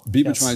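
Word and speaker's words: bemotrizinol: Bimotrizol.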